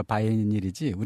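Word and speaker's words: vinyl: 말이 이렇게 멋있게 발음하니까 바이닐이지 우리가 흔히 아는 비닐입니다.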